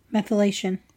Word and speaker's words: methylation: The scientist examined a chemical process termed methylation.